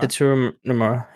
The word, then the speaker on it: Tetsuya Nomura: Uh, Tetsuya Nomura. – Tetsuya Nomura.